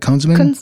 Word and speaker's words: Kunzman: It's K-U-N-Z-M-A-N. I assume it's Kunzman. Does it sound like Kunzman to you guys? Kunzman? – Sounds right, yeah. Sounds sure. – About right. – Sure, sure. – Kunzman, yeah, whatever.